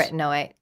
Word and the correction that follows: retinaldehyde: Sorry? Is that the first time you've tried to pronounce that? Retinaldehyde. Yeah. No.